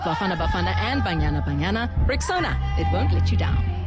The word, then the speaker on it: Rexona: It's pronounced Rexona. It's simple, strong, and easy to remember.